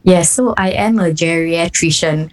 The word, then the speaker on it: geriatrician: Then it's a Montessori kindergarten, so the teacher made them clap out the syllables, like clap it out. It was like geriatrician.